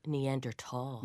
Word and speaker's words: Neanderthals: Neanderthals.